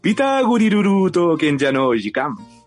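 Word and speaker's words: Peter Grill to Kenja no Jikan: Ah, eh, la, seguimos con la siguiente, se llama Peter Grill no Kenja, eh, no Jikan, o su pronunciación en japo, Pita Guriruruto Kenja no Jikan.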